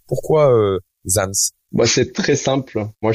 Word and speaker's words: ZamZ: Hein oui, c'est Zams, Zams avec deux Z, un, hein, au début, un hein, à la fin.